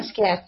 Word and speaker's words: Basquiat: Basquiat, yeah.